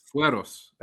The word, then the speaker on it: fueros: Fueros.